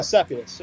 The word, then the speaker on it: Seputus: Uh, Seputus, did I say that right? – Yeah, Seputus. Yeah.